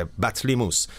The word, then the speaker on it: بطلیموس: پی تی اینطور تلفظ میشه، بطلیموس.